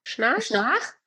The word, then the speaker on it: Schnarch: Magst du noch mal das Buch gerade sagen, was dir so weitergeholfen hat, von dem Daniel Schnarp? – Schnarch? Also den schreibt man Schnarch, wie Schnarch.